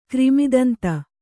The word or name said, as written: ಕ್ರಿಮಿದಂತ